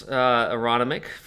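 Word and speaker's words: Aronimink: Aeronomic. That is how you say it, by the way.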